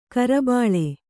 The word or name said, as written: ಕಿರುಬಲ್ಲಿ